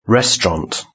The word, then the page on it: restaurant: British English